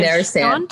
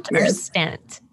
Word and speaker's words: Nerestant: Her last name is pronounced different ways depending on if you're French or American. So Krista Nerestant, if you're French, and then if you're American, it's Nerestant.